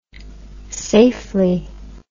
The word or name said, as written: safely